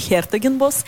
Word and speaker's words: Хертогенбос: А жил он в городе Хертогенбосе и, собственно, на «е» ударение Хертогенбос. И местные жители называют этот город, ну, сложное название города, да, с ума сойдешь, Хертогенбосец говорить просто про себя постоянно, да.